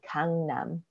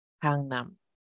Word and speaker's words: Gangnam: I also wanted to go over again the pronunciation for Gangnam, because we talk about a club in Gangnam called Burning Sun. And it's a word that a lot of people are very familiar with, but have not always heard the correct pronunciation for, because of Psy's Gangnam Style song from ages ago. But it's a soft A, Gangnam. And it actually means south of the river.